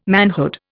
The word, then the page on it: manhood: Indian